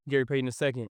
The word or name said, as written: Gary Payton II